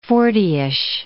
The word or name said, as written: fortyish